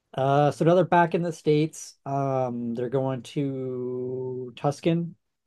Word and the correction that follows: Tucson: Tuxkin. How do you say that? – Tucson.